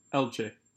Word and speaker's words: Elche: Is it Elche? – Elche, yeah.